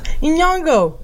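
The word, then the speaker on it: Nyong'o: Iñango. Yeah, people also can't pronounce her last name. – Yeah. You're busting out like the Spanish and then switching right into the Swahili pronunciation.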